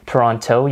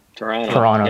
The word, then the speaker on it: Toronto: Another thing is you want to make sure that you don't pronounce it Toronto. You want to not pronounce the second T. It's just Toronto. – Toronto.